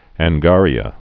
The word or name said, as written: angaria